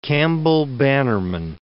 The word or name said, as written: Campbell-Bannerman